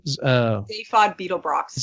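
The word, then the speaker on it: Zaphod Beeblebrox: Zaphod Beetlebrox.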